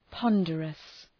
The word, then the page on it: ponderous: ‘pɒndərəs